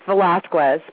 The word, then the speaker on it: Velazquez: Did I say that right, Julie? – It's Velazquez, but very Velazquez.